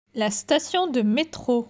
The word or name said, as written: La Station de metro